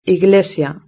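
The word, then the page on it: la iglesia: Spanish (Spain)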